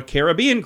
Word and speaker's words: Caribbean: Things like a Caribbean cruise or a Caribbean cruise, depending on how you say it.